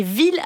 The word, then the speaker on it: ville: Donc c'est vrai, fille et ville s'écrivent presque pareil et se prononcent différemment. Famille, gorille, anguille, aiguille, camomille, en fait presque tous les mots qui se terminent en I2LE se prononcent comme fille.